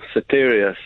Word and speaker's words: Soteria: Soteria, is that how it's pronounced? What's the derivation of the name? – Oh, yes, that's right, yeah. Soteria.